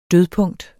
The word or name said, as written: dødpunkt